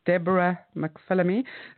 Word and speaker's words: Deborah McPhillamy: They can just go onto my website, which is Deborah McPhillamy. So it's spelled D-E-B-O-R-A-H.